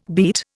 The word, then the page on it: beat: Indian